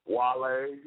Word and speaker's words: Wale: Actually, his name is Whale, but I guess, when you get so much money and you get it in entertainment business, it's pronounced Wale, but it's spelled W-A-L-E.